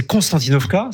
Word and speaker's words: Konstantinovka: Alors, la russe, c'est Konstantinovka, c'est ça. Et l'ukrainienne, c'est Konstantinivka. Donc voilà, alors bon, j'ai la vieille habitude, je suis désolé, mes jeunes années en Russie.